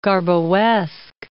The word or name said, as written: Garboesque